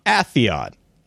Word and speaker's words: Atheon: Kevin Ronaminian sent us a clip because, according to him, his one of his biggest pet peeves is the pronunciation of Atheon. he has a clip from Destiny 2 in which Ghost pronounces Atheon, not Atheon. It's Atheon.